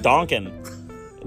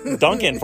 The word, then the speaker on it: Duncan: Well, I like the sign when I used to pronounce it Donkin. Now it's ruined. You've ruined Donkin for me. Duncan for me, I mean.